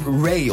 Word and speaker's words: Ray: En Rij is een... – Ray op zijn Engels uitgesproken, ja.